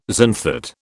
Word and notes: xanthate: British English

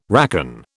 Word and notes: racon: British English

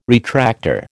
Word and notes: retractor: American English

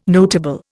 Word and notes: notable: Indian

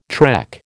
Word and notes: trek: American English

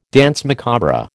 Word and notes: danse macabre: American English

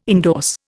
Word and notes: endorse: Indian

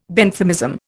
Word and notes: Benthamism: Indian

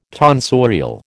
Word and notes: tonsorial: American English

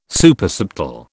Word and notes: Supersubtle: British English

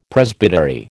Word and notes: Presbytery: American English

